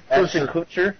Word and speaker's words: Kutcher: How do you say his name? Kutcher. – Ashton Kutcher.